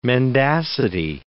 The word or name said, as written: mendacity